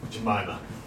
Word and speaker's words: Jemima: It is just me drinking maple syrup straight from the dips of Aunt Jemima. Does this guy know that's not how it's pronounced? Let's find out. I know it's pronounced Aunt Jemima? Fuck, how's it pronounced, sir? – Jemima.